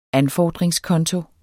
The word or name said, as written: anfordringskonto